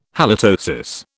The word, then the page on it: halitosis: British English